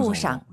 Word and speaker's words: 路上: 이 샹을 선생님 사성으로 해서 원래 사성이잖아요. 路上 해도 괜찮죠? – 可以的. – 선생님은 사실 궁금해요. 선생님 발음이 너무 좋아서 우리 청취자분들과 저는 선생님을 따라가고 싶습니다. 아, 빙시 평소에 어떻게 하세요, 발음? – 칭성. – 아, 경성. 路上. 路上. 우리도 그럼 칭성 경성으로 하시죠. 路上.